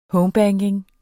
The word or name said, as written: homebanking